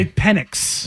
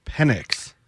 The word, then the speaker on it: Penix: Oh, is that how you say his name? – Yeah, Penix. – Mm-hmm. – Penix. – Yes. – More on the X and less on the IS.